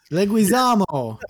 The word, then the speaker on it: Leguizamo: Leguizamo. It's Italian. It's Leguizamo. – Leguizamo? Leguizamo? I don't know. – It's like Leguini, right. But Leguizamo.